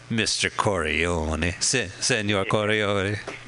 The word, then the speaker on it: Corleone: You pronounce it like the senator at the beginning of John Godfather 2. – Oh, Senator Godfather. Proud to be here with Michael Corleone.